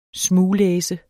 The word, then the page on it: smuglæse: [ ˈsmuːˌlεːsə ]; [ -ˌlεˀsə ]